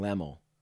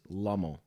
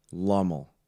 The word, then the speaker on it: Lummel: Every time you talk about Logan, you always say Logan Lemmel. As if it's like L-E, but it is Lummel, like L-O. – Lummel.